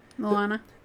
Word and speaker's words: Moana: Mona. – Moana. – The, Moana. See, I don't even say it right. I just thought it was Mona.